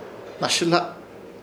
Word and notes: Hnasɨhla: Ishir Chamacoco

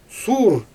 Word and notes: suur: Xyzyl

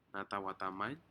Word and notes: natau atamen: Aneityum

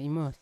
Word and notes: imos: Yawa

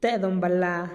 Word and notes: te-donballaa: TdVZ

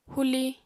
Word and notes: huli: apatani

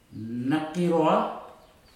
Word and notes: nakiroa: futuna aniwa

